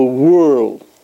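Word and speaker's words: whorl: Now, there's a fancy word for it, it's called the whirl. By the way, that's the H is silent. It's W-H-O-R-L. But it's pronounced whirl.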